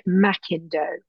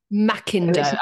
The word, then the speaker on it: McIndoe: He's they pronounced it McIndoe. – McIndoe. – Ah, McIndoe. Okay. okay. doesn't have the A in it, so it makes it difficult to know. But he ah, pronounced it ah, McIndoe, okay. and he was always they were always correcting people. And if you said it to a guinea pig, they would correct you.